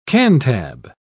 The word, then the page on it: cantab: British English